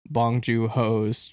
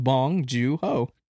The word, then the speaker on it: Bong Joon-ho: Just start off with Bong Joon-ho. – Joon-ho? Bong Joon-ho? – Yeah.